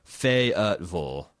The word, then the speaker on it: Fayetteville: So she also gave me the definitive pronunciation guide. It's Fayetteville.